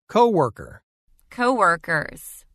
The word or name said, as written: Co-worker – Co-workers